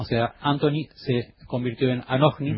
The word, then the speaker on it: Anohni: Anohni. A-N-O-H-N-I. Anohni.